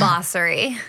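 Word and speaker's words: Bassery: Bossery. – Bossery. I was like, I know I'm going to butcher it. – No. – Ariana Bossery. – Yeah, like the boss.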